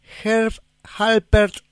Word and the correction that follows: Herb Alpert: and the Tijuana Brass. Herb Alpert, porque vi que es Herb con B larga, Alpert con P. Yo siempre lo digo al revés y ha sido causa acá de alguna corrección.